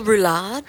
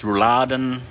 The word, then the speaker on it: roulade: Is it veal roulade? – Roulade, yes. Or I think German it's rouladen.